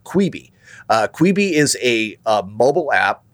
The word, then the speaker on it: Quibi: It's the pronunciation when they sound it out is Kwihbee. So Quibi, and kind of like Kiwi, but Quibi, you know.